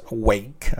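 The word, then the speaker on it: Rwake: Una que es bastante popular ya, pero la otra tiene cierto también su gran fanática La primera es Wake. Se escribe R-W-A-K, pero es Wake, así se pronuncia.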